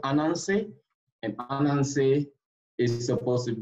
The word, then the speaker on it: Ananse: He's called Anansi, spelled A-N-A. – Okay. – Anansi. – Spell it one more time. You said A-N-A. – A-N-A-N-S-E. Anansi.